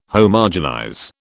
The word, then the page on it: Homogenize: British English